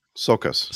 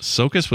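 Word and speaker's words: Csokas: How do you say his last name? – Sokas. – Sokas with a C-S. I guess that's like Sokas.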